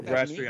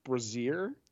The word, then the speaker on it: brasserie: I went to a brasserie on Thursday night. – Not a bistro. – I think it's pronounced brassiere. – No, it's brasserie.